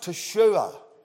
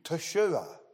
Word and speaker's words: Teshua: It's an interesting word because it is pronounced Teshua. Teshua. And it's very similar to the Hebrew word for Joshua, which is Yeshua.